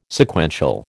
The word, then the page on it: Sequential: American English